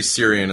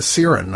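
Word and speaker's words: Ciarán: I always say Syrian, and that's wrong, so. – I think it's a Siren.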